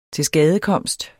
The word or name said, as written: tilskadekomst